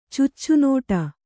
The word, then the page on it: ಚುಚ್ಚುನುಡಿ: cuccu nuḍi; Kannada